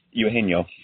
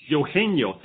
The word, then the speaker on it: Eugenio: But uh, uh, Suarez, okay, how do you pronounce that first name? Eugenio. Eugenio, thank you very much.